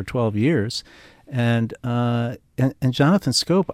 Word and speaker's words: Schoop: Yeah, that's a difficult name to pronounce. It's Scope. Ah, Jonathan Scope. – That's right. – But that's a very tough one. – I forgot, but when you look at it, because it's spelled S-C-H-O-O-P.